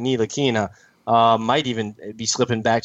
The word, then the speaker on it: Ntilikina: And then the third tier, I've got Monk, Zach Collins, Donovan Mitchell, and Frank Ntilikina.